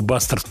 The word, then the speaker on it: бастард: Ну, слово «бастард» в переводе не нуждается, только чуть-чуть сдвинем ударение. Бастард. Empire State Bastard привлекли Дэйва Ломбарда, барабанщика.